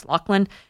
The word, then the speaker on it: Laughlin: And the title of the book, again, it's by Rick Laughlin, L-A-U-G-A, like Laughland, but pronounced Laughlin.